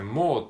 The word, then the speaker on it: more: えっと、これ途中でね、 she was more active って、 more っていうのが出てきたんですけども、これ more なんですけども、まあ、発音記号的にはですね、 more えっと、 R は無視してですね、 more っていうふうに伸ばし棒で、えー、 more っていう感じで、えー、言うっていう風な感じの発音だったと思います。